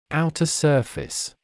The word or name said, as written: outer surface